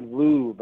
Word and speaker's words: Louv: There's an author by the name of Richard Louv. I believe that's how his last name is pronounced, L-O-U-V.